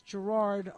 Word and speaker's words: Gerard: But I wanted to make sure that you knew that on my Saturday show, Travelers 411, on January 23rd, I had on Gerard, which is Gerard, only in Ireland they put the accent on the first syllable, so it's Gerard Kennedy.